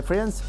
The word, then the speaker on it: Friends: ¿Con The Friends? – Sí. – ¿Qué serie? – Friends, ¿cómo se pronuncia, boludo? Friends. – Sí, la I no se pronuncia.